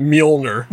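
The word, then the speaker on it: Mjölnir: And how do you pronounce that? – You pronounce it Mjolnir.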